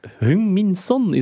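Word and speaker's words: Heung-min Son: Hung Min Son, is dat juist uitgesproken, jongens? Ehm, ja, ik vind het goed.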